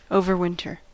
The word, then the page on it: overwinter: American English